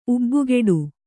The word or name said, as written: ಉಬ್ಬುಗೆಡು